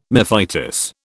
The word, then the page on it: mephitis: British English